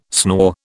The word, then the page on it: snore: British English